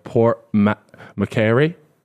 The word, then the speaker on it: Port Macquarie: It was called Port Macquarie.